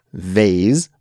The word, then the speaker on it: vase: So there are three different ways to pronounce this word, guys. Vase, like Cassie said, which I believe is the UK English pronunciation and kind of sounds a little bit fancy to our North American English ears, I think, saying it vase.